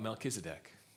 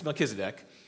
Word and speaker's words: Melchizedek: Then we're going to see about Melchizedek. If you want to know how to say it, I had to read this many, many times. Melchizedek.